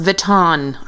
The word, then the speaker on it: Vuitton: Like kittens in Louis Vuitton mittens. – It's pronounced Vuitton. Aren't we all French? – Sam, you hit something.